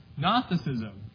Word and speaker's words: Gnosticism: Gnosticism, right? So John is combating Gnosticism, which is spelled with a G for any of you looking it up in the dictionary.